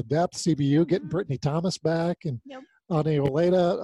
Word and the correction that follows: Ani Olea: Um, I probably mispronounced her name again. Ani Olea, yes.